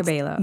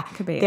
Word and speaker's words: Cabello: Cabello. – Yeah. Cabello.